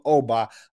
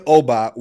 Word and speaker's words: Ogbah: All right, Emmanuel Ogbah, which crazy enough, I'm telling everyone now, you're pronouncing his name wrong the entire time. The G in that situation in Igbo languages is silent.